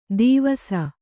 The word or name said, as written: ಧೀವಸ